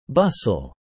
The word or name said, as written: bustle